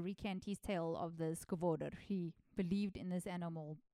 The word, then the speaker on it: Skvader: So Skvader is actually spelled Skvader because it's S-K-V-A-D-E-R, but the pronunciation of the guy is officially Skvader. So yes, that's where I'm at. So if you do read my notes and you see that I've, in your head, been pronouncing it wrong the whole time, it's spelled Skvader, but it's pronounced Skvader.